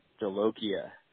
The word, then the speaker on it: Jolokia: All right, I'm staring at the bottle right now, and it's boot, and then the second word is J-O-L-O-K-I-A. So phonetically, that looks like Jolokia to me. – He made that. – You were saying boot Jolika, but I think it's Jolokia.